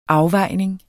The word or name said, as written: afvejning